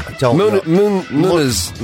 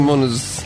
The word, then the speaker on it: Muniz: Frankie Muntz, or Munitz. Oh, how do you pronounce his name? – Munitz. – Frankie Muniz.